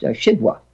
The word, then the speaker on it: Szydło: And her last name is S-Z-Y-D, that Polish L with a strike across it, O. And that's pronounced Shidwa. That L with a W with a strike across it is pronounced as a sort of W.